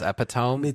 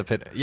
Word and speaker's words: epitome: Sometimes I just read it as epitome, but like I know it's supposed to be pronounced the epitome.